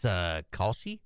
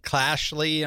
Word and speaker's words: Kalshi: Whether it's Robinhood, Polymarket, Kalshi, how do you say that? – Is it, I think it's Kalshi? – I think it's Kalshi too, but yeah. I hear I hear people talking about it on ESPN or whatever, and they'll say like Clashley. I'm like, huh, am I saying it wrong? – It can't be that.